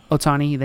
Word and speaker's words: Ohtani: Ohtani, thank you.